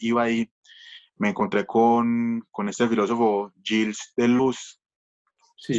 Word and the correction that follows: Deleuze: Supongo que se dice así. – Deleuze, Deleuze.